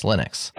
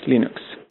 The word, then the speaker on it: Linux: No, because Linus actually has he's on the record as saying, my name is Linus Torvalds, and I call Linux Linux. – Hello, this is Linus Torvalds, and I pronounce Linux as Linux.